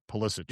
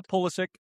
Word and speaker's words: Pulisic: Pulisic. Yep. I'm saying that wrong. Christian Pulisic. Am I saying that right now? – Yeah, Pulisic.